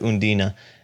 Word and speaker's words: Undine: And I think last time I said that Undine was going to, we're going to pronounce it every single way, but in the film they pronounce it Undina.